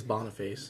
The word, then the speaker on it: Boniface: In the 5th century, Pope Boniface was the... I think that's how you pronounce it. – I think it. – It's Boniface.